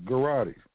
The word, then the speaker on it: Gujarati: Out of that, 70% of them are owned by Gujaratis, and most of them have the surname of Patel, P-A-T-E-L, or Amin, A-M-I-N. All right? Now, because they mentioned something very important, Gujarati. All right, and that's spelled G-U-J-A-R-A-T-I. Once again, Gujarati, G-U-J-A-R-A-T-I, Gujarati.